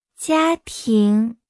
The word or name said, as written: 家庭